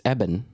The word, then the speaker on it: Eben: And they were pronouncing it Eben? – Yeah, it's Eben. – Hmm. Yeah, I like that better than Evan.